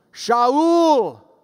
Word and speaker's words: Shaul: And falling to the ground, he heard a voice that knew how to pronounce his name. Shaul.